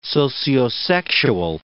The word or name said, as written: sociosexual